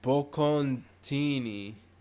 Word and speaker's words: bocconcini: It's pronounced bocconcini. Bocconcini.